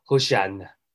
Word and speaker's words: Hosanna: 何塞纳其实他的发音呢是应该是发 Hosanna，你看这听起来就有一点像是这个希伯来文哦。